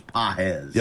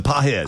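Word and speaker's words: Pages: He retired one man for out number two, but then faced, I've heard this name pronounced about 17 different ways. Andy Paez, Andy Pagese, Andy Paez. Anybody idea? I mean, it looks like. – I think it's Paez. – Yeah, Paez.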